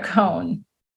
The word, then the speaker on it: Kuhn: Is it Kuhn or Cohn? – Kuhn.